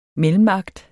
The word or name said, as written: mellemakt